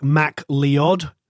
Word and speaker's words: MacLeod: In America, people can't pronounce his name. So if the Americans are listening, it's like Mac Leod.